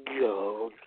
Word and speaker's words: Goldust: You gotta add the emphasis on the T. Goldust.